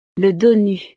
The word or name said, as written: le donut